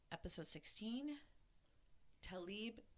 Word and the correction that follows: Taliba: Islam. Taliba. – Mm-hmm. – Taliba, excuse me. Taliba Islam. Mm-hmm. That's how you pronounce it, yeah.